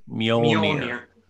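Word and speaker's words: Mjölnir: Mjolnir, whatever, you both got it, that comes to his hand, which means he is worthy to wield the hammer Mjolnir.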